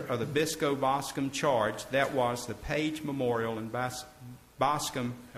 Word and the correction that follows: Bascom: Am I saying that right, Pam? – Bascom. – Excuse me, Bascom.